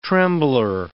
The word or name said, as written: trembler